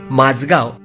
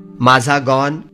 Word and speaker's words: माझगाव: त्याचा मराठी उच्चार माझा गॉन, माझा गोन, माझगोन असे होऊ शकतात. आता ही पुस्तकं वाचताना त्यात आलेल्या माझगावच्या स्पेलिंगकडे सुरुवातीला काही लक्ष जात नाही. आपण सवयीनं माझगाव किंवा माझगाव अशीच ती स्पेलिंग्स वाचतो.